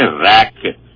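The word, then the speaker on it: RAIC: تو R A I C spelling ہے اس کی شارٹ فارم میں, تو اس کو ریک بولتے ہیں.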